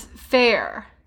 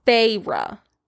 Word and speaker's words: Feyre: And the name is spelled F-E-Y-R-U-H. – Which is why I thought it was Fair. – Yeah, but it's pronounced Feyre.